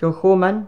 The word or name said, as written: KILCHOMAN